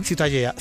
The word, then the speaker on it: Ciutadella: La catalán? Ciutadella. – Ciutadella. Bueno, pues Ciudadela también en castellano.